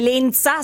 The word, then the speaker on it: Lensa: Cioè, come Lensa, senza la Z, ma con la S.